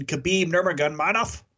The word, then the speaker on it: Khabib Nurmagomedov: And then before that, there was an amazing fight between Khabib Nurmagomedov.